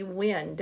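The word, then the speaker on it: Wenner: So it'll be really interesting to see some of the dirt that Mr. co-founder of Rolling Stone magazine, Jan Wiener. It's actually Wen, W-E-N, the emphasis on the W-E-N, Wiener. So Wiener, let me get that right.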